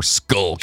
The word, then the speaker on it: Schuylkill: And I even like the way it's spelled, S-C-H-U-Y-L-K-I-L-L. So it's either Schuylkill or Skullkill.